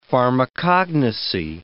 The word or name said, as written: pharmacognosy